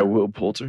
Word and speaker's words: Poulter: Poulter? – Yeah, Will Poulter.